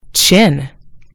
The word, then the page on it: chin: British English